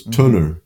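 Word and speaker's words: Tønder: And so I emailed her, and it was a festival called Tunner, which is spelled T-O with a slash through it, N-D, but the D is silent, E-R. And the O with a slash through it is pronounced U. So it's Tunner.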